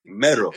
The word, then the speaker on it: Mero: Mero, yeah, Mero. With the accent on the O.